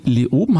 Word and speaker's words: Leoben: Nina, du bist Leiterin des CD-Labors an der Uni Leoben.